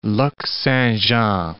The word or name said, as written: Lac Saint-Jean